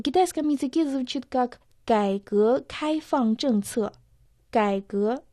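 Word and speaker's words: 开放: «Кайфан» — открытости. «Кай» — первый дон. «Фан» Четвертый дон. Слово «кайфан» в переводе «открытости».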